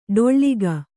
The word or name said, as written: ಢಾಳಕ